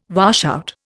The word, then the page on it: washout: Indian